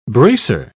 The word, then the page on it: bracer: British English